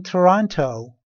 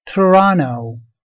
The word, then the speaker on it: Toronto: I live in Toronto. I live in Toronto. البته این شهر رو اینجوری هم میشه تلفظ کرد. Toronto.